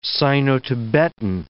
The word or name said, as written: Sino-Tibetan